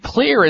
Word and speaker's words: Xlear: And Clear is spelled X as in X-ray, X-L-E-A-R.